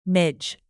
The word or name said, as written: midge